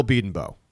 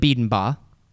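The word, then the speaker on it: Bedenbaugh: We're pronouncing his name different now. Britt Venables didn't pronounce his name different. I don't think the head coach would mispronounce his offensive coordinator's / whatever name. So let's put this to bed. This nonsense. – Bill Beedenbow is a much Beedenbow is a much better name than Beedenbaugh.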